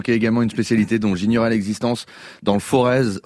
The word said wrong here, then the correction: Forez: enfin non, pardon, le Forêt, sinon je vais me faire, le Forêt, on ne prononce pas le Z final, le Mimi, bouilli de farine au lait.